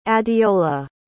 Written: adeola

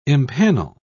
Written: empanel

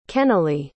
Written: kennelly

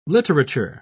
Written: literature

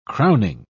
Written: crowning

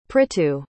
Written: prithu

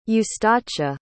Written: eustacia

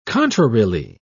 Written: contrarily

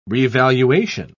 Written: revaluation

